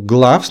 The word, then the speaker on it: gloves: Или вот еще есть слово gloves, но там не двойная O, а но все равно пишется gloves, да, G-L-O-V-E-S, но читается gloves.